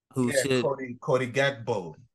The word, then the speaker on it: Gakpo: And then, so that allowed that space for, how you say his name? Gapot or Gapo? – Cody Gakpo.